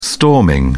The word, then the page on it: storming: British English